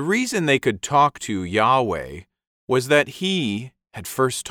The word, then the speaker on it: Yahweh: It was probably pronounced something like Yahweh.